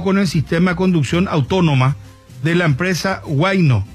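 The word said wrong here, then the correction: Waymo: no sé si se pronuncia así, ¿verdad? O Waino. – Me dice Luis María Morínigo, se pronuncia Weymouth.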